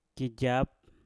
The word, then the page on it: kijap: Mpur